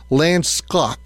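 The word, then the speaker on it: landschap: Landskap. I think that's how you would pronounce it.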